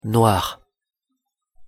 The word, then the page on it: noir: French